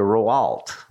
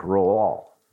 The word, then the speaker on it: Rouault: Now, there are a lot of letters in that last name, but I think you only more or less pronounce the first two. I would look at it and say Roault, but I think the French say something like Rouault, more or less.